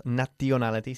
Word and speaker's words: nationality: No decimos nationality, sino nationality. Okay, la T-I-O-N, este sonido siempre se pronuncia como una sh. Shun, ok, shun.